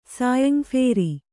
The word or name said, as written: ಸಾಯಂಫೇರಿ